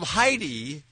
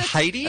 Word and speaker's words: Jedi: And the family spelled Heidi J-E-D-I. – Jedi? – Yes. – And they pronounce it Heidi? – And they said it's Heidi.